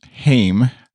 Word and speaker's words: Haim: But, and then at Liren Hollick, we'll say, said, tweeted, small correction. It's Haim, pronounced like Haim.